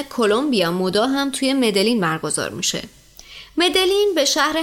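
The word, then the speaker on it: Medellín: خب شهر مدجین یا حالا بعضیا که میگن مدلین بعضیا میگن مدئین، این شهر خب بالاخره از نظر حالا اسمشو بخوام بگم که توی اسپانیایی خب یه یا ج یا ژ بسته به کشورش تلفظ میشه، ولی خب دیگه حالا تو کلمبیا میگن مدجین، بین ج و ی ما هم دیگه میگیم مدجین.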